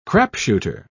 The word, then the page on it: crapshooter: British English